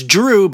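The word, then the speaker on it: Dreux: This is going to involve a little bit of spelling because our number 4 seed is Drew, but let's be very clear. It's D-R-E-U-X, and it's Watermolen.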